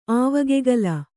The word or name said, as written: ಆವಗೆಗಲ